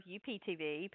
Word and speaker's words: UPtv: Well, oh no, here comes UPTV, Pam and I go back and forth on the proper pronunciation of the network name, but it is Up TV.